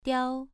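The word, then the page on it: diao: tone 2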